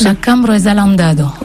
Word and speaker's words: La Cambra es Alandada: La mal coiffée avec le nouvel album L'Embellinaire, le titre qui vient d'être interprété s'appelle La Cambra es Alandada, c'est comme ça qu'on prononce? – La Cambra es Alandada.